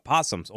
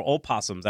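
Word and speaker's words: opossum: Where there is food on the floor, you got not only raccoons, you got possums or opossums. I heard both pronunciations.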